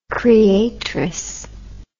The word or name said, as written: creatress